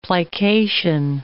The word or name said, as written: plication